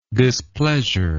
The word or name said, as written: displeasureably